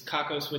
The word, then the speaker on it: Kakos: So, after you left AOL, is Kakos what you started immediately? And is that how you pronounce it? – Yes, okay. Yes. Though for April Fool's, we changed the C to have a Cindy on it, so mm-hmm.